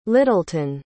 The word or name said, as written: littleton